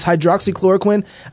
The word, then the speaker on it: hydroxychloroquine: The other thing I want to talk to you about is hydroxychloroquine.